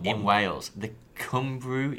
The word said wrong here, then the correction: Cymru: Did you say Cumbrew? How do you say it? Cymru. Cymru.